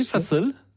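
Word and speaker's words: fasıl: Alors, vous me rectifierez pour la prononciation. – Je vais vous proposer d'écouter un fa-sol. – Oui, fa-sol. Ça veut dire concert en turc, si on le dit comme il faut.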